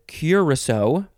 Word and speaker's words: curassow: Next is curacao. So it's pronounced similar to the liqueur, but this is spelled very differently. It is C-U-R-A-S-S-O-W. Curacao.